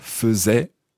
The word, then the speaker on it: faisait: That's from the verb faire, but listen to the pronunciation. – Faisait. – Now, how's it spelled? – F-A-I-S-A-I-T. – Donc, en français, F-A-I-S-A-I-T. In English, F-A-I-S-A-I-T. So, A-I would normally be pronounced in French, of course, as E, as in faisait.